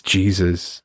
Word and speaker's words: Jesu: She's like, that's Jesus. But obviously in German it's Jesu. Okay. – Kanye Jesu. – Yeah, not Jesus. Jesu. And so my daughter came out saying, Ich habe Jesu gesehen.